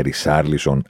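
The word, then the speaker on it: Richarlison: Ότι όχι, δεν θα το πούμε Ριτσάρλισον, θα τον λέμε Ρισάρλισον, αλλά τον Τίτε γιατί δεν το λέμε Τσίτσι.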